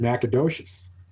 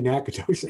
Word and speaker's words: Natchitoches: asking Google how to pronounce it, and they're coming back and fucking Google's pronouncing it Natchitoches.